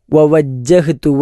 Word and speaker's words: وَوَجَّهْتُ: ഇവിടെ ഉച്ചാരണത്തിൽ നമ്മൾ ശ്രദ്ധിക്കേണ്ടത് ആദ്യം വവജ്ജഹ്തു, രണ്ട് വാവ് ഒരുമിച്ചുണ്ട്. വജ്ജഹ്തു എന്ന് മാത്രമല്ല, വവജ്ജഹ്തു ആദ്യത്തെ വാവിന് ആൻഡ് എന്ന അർത്ഥമാണ്. വവജ്ജഹ്തു, ഞാൻ തിരിക്കുകയും ചെയ്യുന്നു എന്നാണ് അർത്ഥം വരിക.